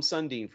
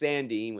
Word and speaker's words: Sandin: You know, the guys like that we talked about earlier, so the guys like Jason Robertson, Rasmus Sandin. It's spelled to say that right. Don't call him Sundin, for God's sake. Rasmus Sandin with the Leafs, they're still trying to sign him and all that happy nonsense.